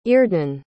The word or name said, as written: iordan